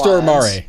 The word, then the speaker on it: Stormare: And it stars Peter, I think I'm pronouncing his last name hopefully correctly, Peter Stormare. – And I believe it's Stormare. – Stormare, okay.